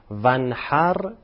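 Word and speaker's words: وانحر: چند وقت سوره کوثر رو میخوندم، فکر میکردم عبارت ونحر با ه دو چشمه، همجوری هم میخوندم، بعد فهمیدم که نه، با ه جیمی هست.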